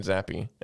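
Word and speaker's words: Zappe: But you do know it's it's actually pronounced Zappy, right? Zappy. It's actually pronounced Zappy. I always thought it was Zapp.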